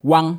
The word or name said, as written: Wang